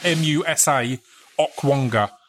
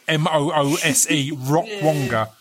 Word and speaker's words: Musa Okwonga: And you have to, like, I need to pause quickly and say his name is Moosa, Musa Okwonga, Okwonga, because when I've mentioned him before, I've had people go, I can't find Moose, Moose Okwonga.